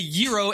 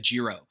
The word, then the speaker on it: gyro: Okay, it's not a gyro, it's a gyro, and the G is silent. Like George in Greek is Yorgos, right? Like the G right there in the beginning is a Y, right? That's the same with a gyro, okay?